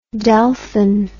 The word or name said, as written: delphin